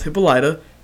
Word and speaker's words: Hippolyta: Also, her name is pronounced Hippolyta. It's Greek.